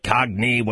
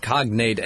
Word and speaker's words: cognate: Cognate, cogni, whatever. – Yeah, it's cognate, N-A-T-E, not a cogni.